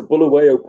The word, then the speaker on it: Bulawayo: Was it the Baleo, I can't pronounce it. Baleo pattern? – Almost. It's a Bulawayo pattern pith helmet.